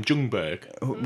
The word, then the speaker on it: Ljungberg: Because the J you don't pronounce. – Yeah, I know, but we've got a choice here. Like, why don't we just not pronounce the L and call him Ljungberg? – We can, but it's not his name.